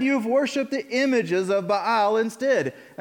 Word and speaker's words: Baal: And I'm going to keep saying Baal because there should be a little apostrophe in those two A's because that's what it sounds like when you read his name in Hebrew. So Baal, there you go. You guys say it with me. 3, 2, 1. Baal. All right. It's just how it's said.